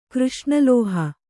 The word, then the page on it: ಕೃಷ್ಣಲೋಹ: křṣṇa lōha